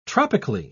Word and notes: tropically: British English

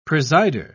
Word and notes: presider: British English

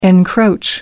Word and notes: encroach: British English

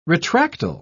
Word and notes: retractile: British English

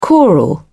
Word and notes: choral: British English

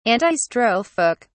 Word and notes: antistrophic: British English

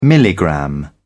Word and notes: milligram: British English

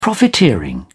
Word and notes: profiteering: British English

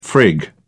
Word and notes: frig around: British English

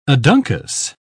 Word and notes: aduncous: British English

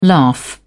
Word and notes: laugh: British English

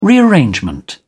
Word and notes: rearrangement: British English

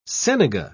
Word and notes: senega: British English

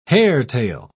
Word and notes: hairtail: British English

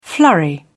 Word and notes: flurry: British English

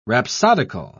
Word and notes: rhapsodical: British English

